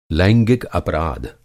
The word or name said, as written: लैंगिक